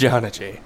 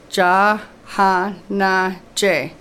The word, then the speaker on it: Jhanaji: And for new listeners, it's Ja Ha Na J. – So that's four syllables. Ja Ha Na J. – Now say it fast.